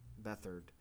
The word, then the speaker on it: Beathard: They returned starting QB, CJ, I don't know how to pronounce Beathard. His name. – Beathard.